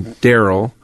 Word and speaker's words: Daryl: Daryl, D-A-R-Y-L, and get $28 tickets instead of $35.